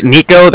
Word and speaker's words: Niko: And then my name's Nico, that's spelled N-I-K-O. And the Bank of Cardiff is spelled C-A-R-D-I-F-F, as in Frank.